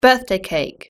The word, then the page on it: birthday cake: British English